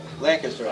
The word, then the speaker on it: Lancaster: Lancaster, actually. That's how they say it. I get corrected all the time.